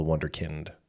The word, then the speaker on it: Wunderkind: The Wonder Kid, or as he likes to remind people, the Wunderkind, because that's how you say it.